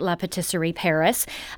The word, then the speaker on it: La Patisserie Paris: And for you French purists, that is how they're pronouncing it, La Patisserie Paris.